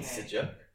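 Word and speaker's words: sucuk: I would put this in, which is it's quite dirty, but it's like a Turkish garlic sausage called sujuk. – Is that how you pronounce it? – Yeah. – Sujuk. – Yeah. – But it's S-U-C-U-K.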